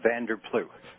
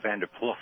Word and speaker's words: Van der Ploeg: It's actually Van der Ploeg. – Van der Ploeg. – Silent G. – Silent G. – Actually, you pronounce it the way they do in Holland, Van der Ploeg.